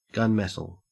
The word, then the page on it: gunmetal: Australian English